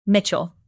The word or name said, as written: mitchell